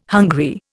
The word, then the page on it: hungry: Indian